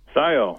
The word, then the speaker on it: Scio: Scio. – Scio. S-C-I-O.